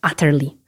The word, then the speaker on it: utterly: Jednym z takich przysłówków jest utterly, pisane przez U, ale wymawiane jako A. Oczywiście dwa ty i jedno l na końcu.